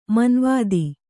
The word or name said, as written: ಮಾನವತೆ